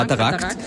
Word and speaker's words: Katarakt: Und drauf stehen so Dinge wie Makuladegeneration oder Katarakt, oder wie spricht man es richtig aus? – Ja, genau, Katarakt.